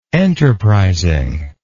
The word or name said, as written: enterprisingly